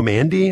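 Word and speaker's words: Mande: Mike Scherr, comedian Joe Mandy, is that how you pronounce it? – Yes.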